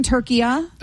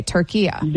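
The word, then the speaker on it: Türkiye: And so now the instruction is that also in English, say it like how it's essentially supposed turkiya. – To be pronounced. Okay, turkiya. – There you go. That's perfect. That's how it's pronounced. It's always been pronounced that in Arabic language.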